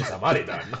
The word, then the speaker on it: Samaritan: Ah, creo que hay que pronunciarlo así, Samaritan.